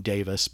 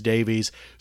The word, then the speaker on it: Davies: And technically, Ray pronounces his last name Davis, but there are too many people in the world who mispronounce it as Davies.